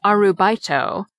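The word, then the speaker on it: Arubaito (アルバイト): You'll be searching for an Arbeit job, pronounced Arubaito, after the German word Arbeit, which means employment or work.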